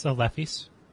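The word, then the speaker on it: Lephise: Am I pronouncing that right? Lefis? – Yeah, Lefis.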